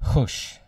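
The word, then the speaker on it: chush (חוּשׁ): The Hebrew word for make haste is the word chush, spelled H-U-S-H, but it's pronounced with a ch, chush.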